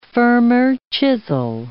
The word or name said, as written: firmer chisel